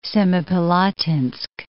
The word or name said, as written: Semipalatinsk